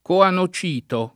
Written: coanocito

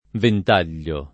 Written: ventaglio

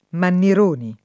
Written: Mannironi

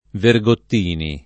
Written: Vergottini

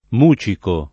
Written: mucico

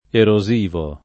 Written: erosivo

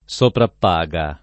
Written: soprappaga

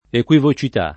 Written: equivocità

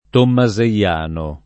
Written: tommaseiano

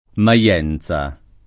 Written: Maienza

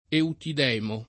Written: Eutidemo

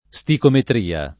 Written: sticometria

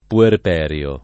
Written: puerperio

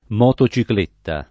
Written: motocicletta

